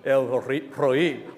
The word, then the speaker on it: El Roi: There's this Hebrew word, I'm going to butcher the pronunciation, but it's El, there's like a Spanish R rolling at the beginning. Roi. El roi.